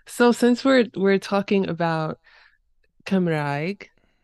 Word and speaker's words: Cymraeg: And so Cymraeg and Cymraeg are both perfectly acceptable ways of saying it. And it just depends on where you are in Wales.